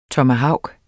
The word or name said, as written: TOMAHAVKEN